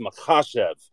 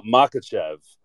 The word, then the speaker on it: Makhachev: It's Makhachev. It's not Makhachev, which is kind of the standard American pronunciation. Like, that's just the default way that we would say that when we read it.